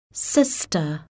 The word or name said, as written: sister